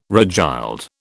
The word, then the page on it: regild: British English